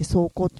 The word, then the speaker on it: 霜降: この時期は霜降という時期で、霜が降りますよと、霜と書いてね、降りると書いて霜降と読むんですよ。